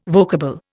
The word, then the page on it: vocable: Indian